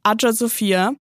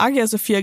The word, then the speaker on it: Hagia Sophia: Denn hier liegt auch die Adja Sophia, da spricht man wirklich so aus. Ich habe früher auch immer Agia Sophia gesagt, aber scheinbar ist Hagia Sophia die richtige Version.